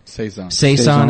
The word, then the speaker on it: Saison: And is it Saison? Is that how you say it? – Saison ales.